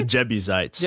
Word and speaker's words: Jebusites: And the Jesusites. Yeah. Jesusites? – The Jebusites.